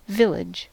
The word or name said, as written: Ort